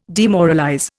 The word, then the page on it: demoralize: Indian